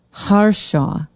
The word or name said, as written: harshaw